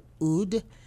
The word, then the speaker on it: oud: We also have Wissam Siraji, playing the Middle Eastern string instrument known as the oud.